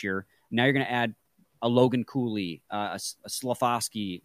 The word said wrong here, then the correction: Slafkovsky: I always mispronounce that name, but you know who I'm talking about. – Yeah. – That's going to be dangerous. Slafkovsky, rather.